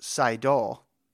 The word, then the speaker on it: Seidel: And he says that he heard a recent interview where Molly said her last name is pronounced Seidel. Seidel. – Oh, man. – Seidel. – Seidel. – Seidel. – Okay. – Seidel, not Seidel.